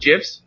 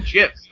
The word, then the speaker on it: GIF: Yeah, I call them GIFs. I don't know. – Well, the thing is, it's graphic interface format or whatever it is, graphic imaging format. So graphic is a G. It's a hard G.